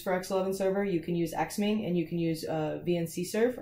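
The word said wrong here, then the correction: VcXsrv: Let me make sure that I'm actually saying that right. VCXSERV, yes.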